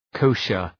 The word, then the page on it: kosher: ‘kəʋʃər